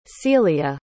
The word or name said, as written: celia